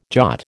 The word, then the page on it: Jat: American English